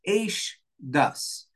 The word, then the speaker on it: Eish Das: And it's for this reason that the Torah refers to the Torah itself here in Parshas Vezos Habracha as Eish Das. It's written as one word and it's pronounced as two words.